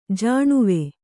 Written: ಜಾಣುವೆ

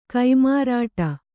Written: ಕೈಮಾರಾಟ

